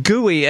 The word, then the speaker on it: GUI: Right, GUI, and that's how I generally hear GUI pronounced.